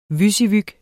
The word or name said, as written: WYSIWYG